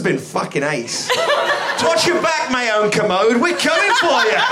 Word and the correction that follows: Kermode: It's pronounced Kermode.